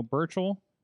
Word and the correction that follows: Burch: Is that the right name? Am I adding some syllables there? – I don't know. – Daniel Burch. – Daniel Burch. – Danny Burch. Thank you.